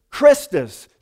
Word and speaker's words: Christus: named Christus, which was the pagan pronunciation for the Greek name for Jesus or Messiah, which is Christus, C-H-R-I-S-T-U-S.